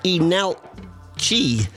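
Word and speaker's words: Leenalchi: Seven-piece band, they're called Enalchi, although spelt with an L at the start.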